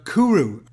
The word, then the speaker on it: Cwyr: Okay, and now the next new release that's come out of Libertino this week is by an indie band called Sybus, S-Y-B-S, and the latest track of theirs is called Kuru. It's spelled C-W-Y-R.